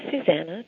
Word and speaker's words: Susanna: My first name is Susanna, just like in the song, Oh Susanna. And yes, it rhymes with banana.